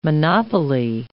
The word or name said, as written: monopoly